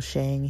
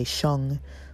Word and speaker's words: Sheng: He pronounces his name Leo Shang. He says that most Mandarin speakers would actually say Sheng, but he says Shang, and so he says that's how he introduces himself. And so I will say Shang.